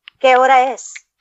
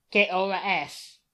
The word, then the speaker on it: ¿Qué hora es?: Hora, which is H-O-R-A, hora. – Yeah? – Es, E-S. ¿Qué hora es? – ¿Qué hora es? – It's 60 minutes with Dan Rather. Yes, ¿qué hora es? – Mm-hmm.